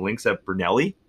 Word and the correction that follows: Brunello: Oh, Brunello. Yeah, Lynx at Brunello. Oh, there's an O there.